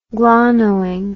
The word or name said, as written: glonoin